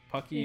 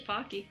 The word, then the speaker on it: Pocky: Is it No Pocky or No Pucky? Yeah. Or I think it's Pocky. – Like, Pocky.